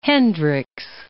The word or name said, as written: Hendricks